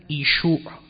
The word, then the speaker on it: ایشوع: و اصل این واژه سوریانی است، زبانی است به نام سوریانی که این زبان انجیل اصلی بوده است و تلفظ اون در خود سوریانی با همزه اول ایشوع، همزه اول بعد یه شین واو عین، ایشوع که گاه همزه می افتاد و می گفتند یشوع یا یشوعا، این میشه عیسی.